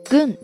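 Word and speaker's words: 軍: 呃，其实，在日语当中，军队的军，它的发音呢也读作 gun，对不对？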